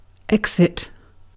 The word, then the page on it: exit: British English